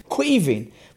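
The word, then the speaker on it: Caoimhín: Queaving, which people can now pronounce because of the Liverpool goalkeeper.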